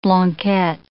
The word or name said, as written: blanquette